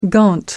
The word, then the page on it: gaunt: American English